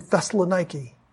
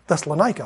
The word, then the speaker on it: Thessaloniki: I've talked to him twice, and he lives in Thessaloniki. That's how it's pronounced today. That's how it's on the map. But Thessalonica, I'm sure it's not on the actual, you know, the memorial grounds or the actual foundations.